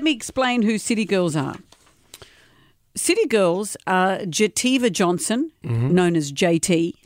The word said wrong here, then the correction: Jatavia: Young spelt Y-U-N-G. – Jativa's spelt with a J. – Jatavia.